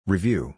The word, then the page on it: review: long-u